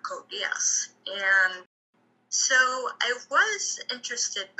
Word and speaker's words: Code Geass: One of my friends actually recommended me an anime called Code Geass. Before, I thought it was called Code Geass, but that wasn't the right pronunciation.